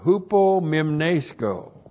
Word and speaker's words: hupomimnesko: And then we have the verb hupomimnesco. Hupomimnesco. Looks like that in the Greek, H-U-P-O-M-I-M-N, long E-S-K, long O.